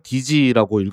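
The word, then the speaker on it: DSEE: 그래서 표기는 DSEE로 하는데 발음할 때는 DG라고 읽었다고 합니다.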